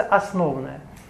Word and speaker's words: основное: Это тело называется основное. Ну или основное, но лучше вот ударение стоит на основное.